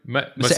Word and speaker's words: Mosseri: Mosseri.